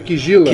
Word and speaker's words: Kizila: A pronúncia certa em banto é Kizila.